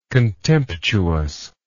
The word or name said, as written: contemptuousness